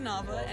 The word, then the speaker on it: Nava: N-A-T-A-L-Y, Nava, N-A-V-A.